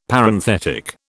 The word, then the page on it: Parenthetic: British English